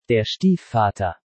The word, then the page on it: Der Stiefvater: German